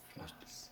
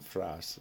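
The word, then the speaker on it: Frasz: Frass. – Mother always called him Frass.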